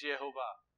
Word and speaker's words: Yehovah: So if you ask any, there's 17 Jewish rabbis that have been on record that come out and said that the pronunciation has always been known. And he said, well, what is it? It's Yehovah.